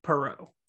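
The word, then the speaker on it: Parrott: It's spelled like parrot, but it's not pronounced the same way. It's Perreault, the French.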